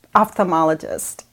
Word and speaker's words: ophthalmologist: So I said a little bit earlier before we got started, if went over last night how to pronounce ophthalmologist, and I think I pronounced it wrong.